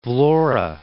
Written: Vlore

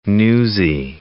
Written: newsie

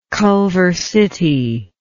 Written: Culver City

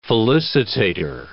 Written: felicitator

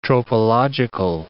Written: tropological

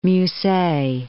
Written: Musset

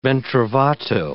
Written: ben trovato

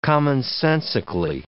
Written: commonsensically

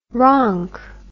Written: ronk

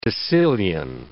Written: decillion

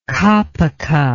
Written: kapuka

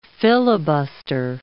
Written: filibuster

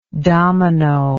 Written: dominoed

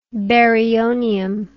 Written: baryonium

